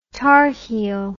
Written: tarheel

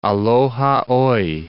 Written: aloha oe

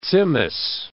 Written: tzimmes

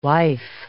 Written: wife

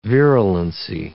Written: virulency

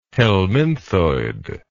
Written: helminthoid

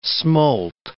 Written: smolt

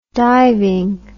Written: diving

